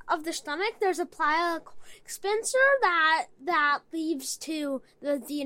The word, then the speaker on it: pyloric: And I looked up, it's pyloric sphincter.